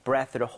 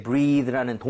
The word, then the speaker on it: breath: 그래서, 본인의 breath를 hold 한다는 것은 이거는 숨을 참다가 되겠습니다. – 네네네. – 그리고 이게 breathe라는 동사가 아니에요. 그죠? – 응응. – 숨이라는 명사란 말이에요. 그래서 발음할 때 breathe 이게 아니라 breath.